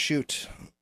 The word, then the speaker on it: Shught: So anyway, after a long time of getting over that, I just released this thing, Shoot. It's pronounced Shoot. – How's it spelled? – Spell it out, dude. – S-H-U-G-H-T. – That's a tough one. – S-H-U-G-H-T.